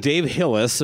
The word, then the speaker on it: Dave Hillis: I would like to welcome to the program Dave Hillis. There's zero chance I mispronounced the name, correct? – Yeah, it's a pretty easy one.